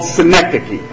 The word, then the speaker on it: synecdoche: But there's a word in the English language called synecdoche. Alright, it's spelled S-Y-N-E-C-D-O-C-H-E, and I can give it to you after service as you want, but a synecdoche.